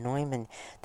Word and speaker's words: Neumann: That was the name we will call him St. John Neumann, but I believe the correct pronunciation is St. John Neumann, the Bishop of Philadelphia.